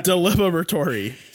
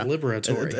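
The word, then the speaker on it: Diliberatore: Deliberatory. So, Peter deliberatory. Yeah, deliberatory. – It's a whole bunch of syllables. You gotta throw in the E sound at the end.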